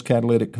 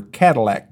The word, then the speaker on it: catalytic: Yeah, those catalytic converters were terrible. And then people forgot how to pronounce them. They thought they were Cadillac converters.